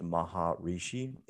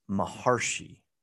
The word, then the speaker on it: Maharshi: His name is not pronounced Maharishi, even though those are two actual words. Maha means great, Rishi means sage or seer. It's Maharshi, and it's like, it means Maharishi, but it's spelled and pronounced Maharshi.